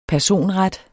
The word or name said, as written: personret